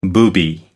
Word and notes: booby: American English

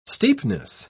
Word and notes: steepness: British English

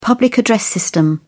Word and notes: public address system: British English

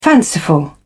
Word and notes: fanciful: British English

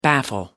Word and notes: baffle: American English

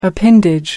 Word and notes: appendage: American English

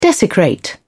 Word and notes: desecrate: British English

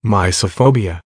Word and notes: mysophobia: American English

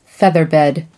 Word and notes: featherbed: American English

